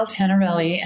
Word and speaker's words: Tenerelli: My name is Danielle Tenerelli, and it is T-E-N-E-R-E-L-L-I.